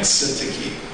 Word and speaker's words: Syntyche: You know, Nancy did such a great job of reading and pronouncing Yodia and Syntyche. I struggled with the pronunciation, and I finally came up with odious and soon touching because these two ladies are arguing and they're having trouble, and because of their arguments, the church is not of one mind.